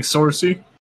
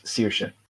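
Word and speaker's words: Saoirse: It's like Sorcy. – Nope. – How's it pronounced? – Sorcy? – Sorcy? – You're never going to get it. It's Searsha.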